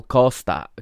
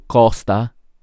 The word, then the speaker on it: Costa: Yasmin would hit an in swinger again, and this time Carole Costa, Carole Costa, excuse me, uh, I don't know why I pronounced her name in Spanish there for a moment. Carole Costa, Costa, sorry, uh, would head the ball clear and uh, avert the danger.